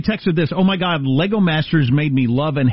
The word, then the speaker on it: Lego: But I learned that I'm not supposed to pronounce Legos with an S. WTF, the plural for Legos is our Lego.